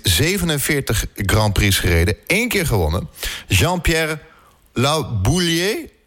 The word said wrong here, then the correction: Jabouille: Ik zeg het waarschijnlijk niet goed. Jabouille. Ja.